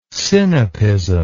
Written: sinapism

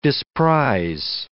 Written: disprize

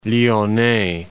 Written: Lyonais

